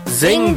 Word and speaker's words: Zeng: Well, you should check out the Zing This podcast, and that's spelled Z-E-N-G